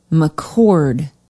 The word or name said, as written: mccord